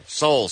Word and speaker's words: Seoul: It rhymes with Grohl. – It's a South Korean city that rhymes with Grohl. – Cole. – I mean, technically, that rhymes with Grohl. – It does rhyme with Grohl. – Right, but that's incorrect. – Seoul, South Korea is the name of this. Seoul.